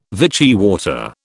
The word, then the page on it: Vichy water: British English